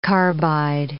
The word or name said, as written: carbide